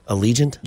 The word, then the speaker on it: Allegiant: Allegiant? – Yeah.